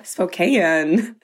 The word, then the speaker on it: Spokane: Spokane.